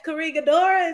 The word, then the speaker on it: Corregidora: Yes, yes, Corregidora.